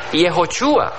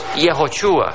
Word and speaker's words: Yehoshua: El nombre Josué es de descendencia hebrea, y en el verdadero hebreo se pronuncia Yehoshua. ¿Ustedes hablan hebreo, hermanos? ¿José, hablas hebreo? Poquito. Vamos a decirlo juntos, Yehoshua.